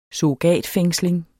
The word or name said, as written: surrogatfængsling